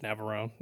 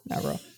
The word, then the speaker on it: Navarone: And then 1961's The Guns of how do you pronounce that? Navarone? – I think it's just Navarone. Yeah. – Yeah, Navarone. And